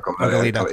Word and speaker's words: Goleta: Goleta. It should be pronounced Goleta. You got it right.